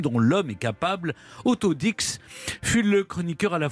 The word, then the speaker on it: Dix: Même si ça s'écrit, vous voyez, comme ça, on dit Dix.